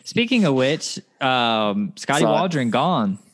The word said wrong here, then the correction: Scotty Walden: Okay, get his name right first, at least. – No. – Yes. – He has to make a name for himself. – Scotty Walden.